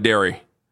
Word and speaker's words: Dery: Pronounced like Derry. Like the food group. Spelled D-E-R-Y.